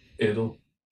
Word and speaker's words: Edel: The director, Uli Edel, am I saying that right? – Edel. – Mm-hmm, perfect.